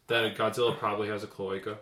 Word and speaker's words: cloaca: Okay, cloaca is how you say it? – Yeah.